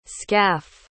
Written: skaff

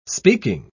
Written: speaking

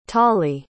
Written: tallie

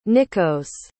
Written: nikkos

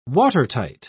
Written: watertight

